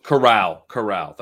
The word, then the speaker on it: Corral: Howell, Willis, Pickett, it's like Carroll or I'm pronouncing it wrong. – Oh, Matt Carroll or whatever. – Yeah. – Doug is Erica's new alt. – Corral. That's what it is.